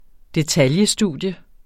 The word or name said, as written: detailstudie